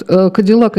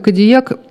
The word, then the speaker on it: Кадиллак: Вот Кадиллак и Кадильяк – это из-за французского написания фамилии, которое по-английски читается по-другому.